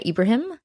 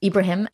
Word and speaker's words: Ebrahim: So I say Salimah Ebrahim. – Ebrahim. – Ebrahim. It's interesting about my last name. It's spelled E-B-R-A-H-I-M. And it's really helped me navigate borders in an interesting way just for that reason. Abraham with an A, or Ibrahim with an A, is traditionally Jewish, and I is Muslim.